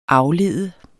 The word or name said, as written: aflede